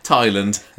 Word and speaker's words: Thailand: Thailand. Yeah, you know. That's how you say it.